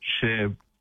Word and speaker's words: Cherbourg: So with those drier and warmer conditions, there's a few pockets of higher fire danger in the area around Cherbourg. Tell me if I'm pronouncing that right. – That's correct. – Cherbourg. Okay. Cherbourg, right. I had a crack at pronouncing it the French way, but okay, Cherbourg, yep.